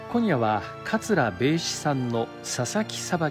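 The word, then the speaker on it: 米紫: 桂米枝さん、お米に紫と書いて米枝と読みますが、 1974 年京都市のお生まれです。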